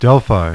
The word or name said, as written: DELPHI